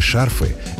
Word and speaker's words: шарфы: Многие односложные существительные мужского рода во множественном числе действительно переносят ударение на окончание. Но шарф к этой группе не относится и ударение не меняет. Поэтому правильно теплые шарфы, вязаные шарфы, зимние шарфы. Запоминаем – зонты, мосты, но шарфы.